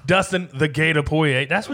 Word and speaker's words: Poirier: UFC 257, McGregor versus Poirier. Hope I said that right. – Poirier.